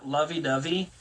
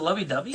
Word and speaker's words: lovey-dovey: But I also kind of tweak a little bit, like, if they're not super lovey-dovey and dubby? – Yeah, read your clock. Dubby or dubby? Is it lovey-dubby? – Dovey. Like a dove. Like the a bird. – Lovey-dovey. Yes. Like it rhymes.